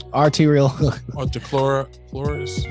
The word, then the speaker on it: arteriosclerosis: Arteriosclerosis.